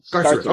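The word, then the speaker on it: Skartaris: So Sakaris, I think is the name of the world that he was in. – Scarterus. – Okay, good. Yeah, I'll go with pronunciation more over mine.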